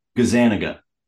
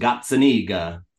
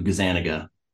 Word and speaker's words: Gazzaniga: That is Italian, but over in Italy, it's pronounced Gazzaniga, and it's over the generations has gotten Americanized to Gazaniga.